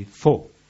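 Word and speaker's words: Fo (佛): which in Chinese is simply Fo. Fo is their pronunciation of Buddha. If they hear Buddha and try to pronounce, it doesn't work, so it comes out Fo.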